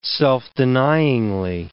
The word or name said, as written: self-denyingly